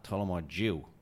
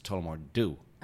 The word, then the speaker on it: Tullamore Dew: And Alan, you say, it's not Tullamore Jew, it's Tullamore Do.